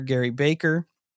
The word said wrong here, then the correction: Gray Barker: I don't know if I said his name right. Is it Gary Baker? Gray Baker. Gray Barker.